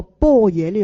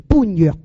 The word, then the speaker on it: 般若: 咁同埋咧，如果我哋唔系有学佛有听经闻法嘅话，我哋亦都会将呢个波野呢样个字咧，会读成般若嘅、哦。